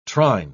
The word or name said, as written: trine